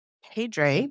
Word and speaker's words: Pedre: And while Dr. Pedre, did I say your name correctly? – Yeah.